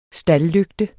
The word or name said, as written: staldlygte